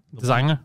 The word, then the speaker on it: designer: Designer with a silent G. Mm. – Yeah. – Or a G that's pronounced always the same way when there's an N after.